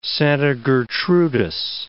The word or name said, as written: Santa Gertrudis